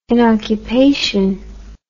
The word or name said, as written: inoccupation